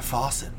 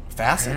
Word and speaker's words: facet: I'm telling you, we gotta go down that faucet. Facet.